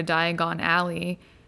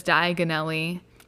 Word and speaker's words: Diagon Alley: Remember also in Chamber of Secrets, in the next book, Harry tries to go to Diagon Alley, and he says Diagonelli instead, and he ends up not where he wants to be.